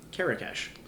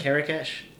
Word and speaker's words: Karakesh: Karakesh. – Karakesh. – Yeah, kind of like Carrie Cash or Karakesh, like Marrakesh, except okay. Karakesh.